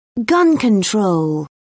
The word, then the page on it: gun control: British English